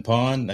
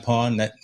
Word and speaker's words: Pwned: I've been pawned. I think, have I been pawned? It's spelled weird, PWNED,